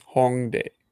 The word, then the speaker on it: Hongdae: And how do you say it again? – Hongdae. – Hongdae. Okay.